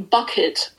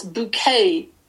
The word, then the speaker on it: Bucket: In the former, housewife Hyacinth Bucket dedicates her existence to just that, keeping up appearances, obliging all and sundry to pronounce her surname Bucket as Bouquet, and terrifying her friend and neighbour Elizabeth into having accidents with her royal Dalton tea service.